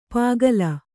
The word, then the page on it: ಪಾಗಲ: pāgala